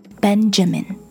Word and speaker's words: Benjamin: David 给的例子就是 Benjamin, B-E-N-J-A-M-I-N, Benjamin, Benjamin. 你听得到 J 这个音吗？ Benjamin. 但是他说，讲德语的人会把这个名字念成 Benjamin, see? J 这个音就没有了。